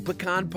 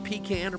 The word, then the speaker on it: pecan: Baking pecan pie, and whether you pronounce it pecan or pecan, it's a Southern staple since the harvest season falls between September and December.